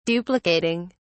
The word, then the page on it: duplicating: British English